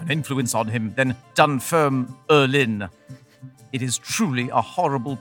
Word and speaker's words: Dunfermline: That has four syllables when you say it. Dunfermline.